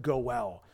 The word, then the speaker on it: goel: I think that's an unfortunate translation because the word is goel. It's the Hebrew word. We would pronounce it goel.